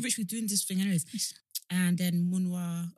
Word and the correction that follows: Manya: Manya. – Why am I saying it wrong? Manya.